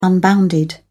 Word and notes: unbounded: British English

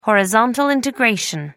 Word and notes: horizontal integration: American English

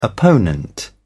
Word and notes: opponent: British English

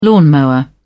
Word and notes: lawnmower: British English

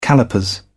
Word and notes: callipers: British English

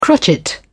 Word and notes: crotchet: British English

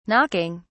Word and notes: knocking: British English

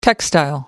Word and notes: textile: American English

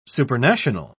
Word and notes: supernational: British English

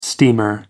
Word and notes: steamer: American English

